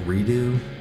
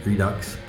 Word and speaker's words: redux: Mr. Fancy I always thought it was pronounced redo, but it's not. It's pronounced redux. First heard it in conjunction with the book Rabbit Redux by John Updike, which was an author that I liked very much when I was in high school.